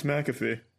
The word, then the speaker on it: McAfee: It's McAfee.